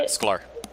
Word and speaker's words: Sklar: The Disney Imagineer, Marty Sklar, is that how you pronounce it? – Yeah, Sklar.